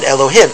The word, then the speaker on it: Elohim: That's a small g, and that is said Elohim. Anytime that word is not used in connection with the Ribono Shel Olam, it is pronounced with a hey.